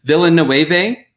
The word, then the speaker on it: Villeneuve: They only wanted to give money for one because Villeneuve, that's how you say his name.